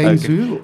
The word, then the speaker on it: Guangzhou: Okay, and that's Guangzhou. Am I saying it right? – Yeah, Guangzhou.